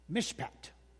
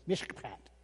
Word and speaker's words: mishpat: It's mishpat. Now you don't have to like that so much, but you got to spit a little bit. Mishpat, okay. So M-I-S-H-P-A-T, if you're trying to spell it. People always ask me, how do you spell that? Well, there it is. M-I-S-H, mish, just like it sounds, mishpat.